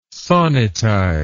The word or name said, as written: sonnetize